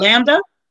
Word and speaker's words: Lambda: Lambada? It's not Lambda? – Lambda? I don't have my glasses on. Let me spell it. Hold on. – Lambda. – Lambda. – Lambda. Yeah, because they're all Greek alphabets.